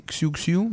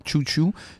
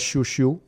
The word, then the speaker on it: Xiu Xiu: Donc, moi, je dis Xiu Xiu. Semble-t-il faut prononcer Chou Chou. – Moi, je dirais Chou Chou.